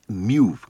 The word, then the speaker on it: Mew: Bien, ahí acabamos de escuchar a los daneses Mew, creo que se pronuncia así, M-E-W, Mew, con su tema Apocalipsco.